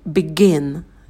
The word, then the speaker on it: begin: Первый – begin. И, краткий и. Потом began, звук лягушка, когда мы улыбаемся, чуть отстраняем вниз и быстро ставим на место. Begun, краткий а, рот минимально открывается.